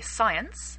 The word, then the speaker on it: science: Okay, science. Remember the word science. It has three vowels in it. The last vowel, the E, is silent. So, of course, we don't say science-y. It's a silent E.